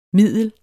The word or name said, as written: middel